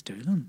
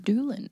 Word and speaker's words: Doolan: I thought it was Dolan. – No, I think it's Doolan. – Doolan. – Yeah. – Doolan. – Yeah. – D-O-O-L-A-N.